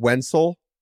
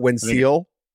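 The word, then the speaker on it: Wenceel: You know, we want to try and get pronunciations right, but the fact that it's W-E-N-C-E-E-L and is pronounced Wenzel or Wenzel not Wenzel.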